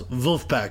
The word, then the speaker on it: Vulfpeck: And by the way, if you want to find out more about that, Wolfpack is spelled V-U-L-F-P-E-C-K. – It's spelled exactly how it sounds, Wolfpack.